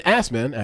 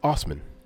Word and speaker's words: Osman: When Assman, actually, guys, I'm going to continue to call him Assman because that's the way it's spelled. – Right. – But it's Osman.